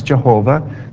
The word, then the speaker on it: Jehovah: Now, the Lord is Jehovah in the Old Testament, sometimes pronounced Yahweh, sometimes Jehovah. The original name for God has no vowels in it, so you can't really pronounce it. And the Jewish people did that on purpose because they thought the name of God was too holy to pronounce. So through the course of time, folks put vowels in, and one way to say it is Jehovah and another way is Yahweh.